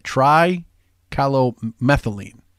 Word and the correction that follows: trichloromethylene: So I really want to say it correctly. It's trichloromethylene.